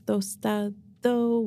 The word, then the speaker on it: Tostado: They're like, is it tostada? And I'm like, no, it's tostado with an O.